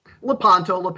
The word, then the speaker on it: Lepanto: So the Lepanto Institute, that's it. Am I saying that right? – Lepanto.